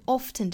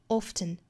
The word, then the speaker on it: often: 后面这个 often 这个词呢是有两种读法的，不管英音还是美音都会有可能把这个 t 的音读出来，读成 often 或者是 often, often, often。当然还有一种读法就是 t 这个音不发，我们可以读成 often, often, often, often。